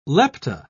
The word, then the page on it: lepta: British English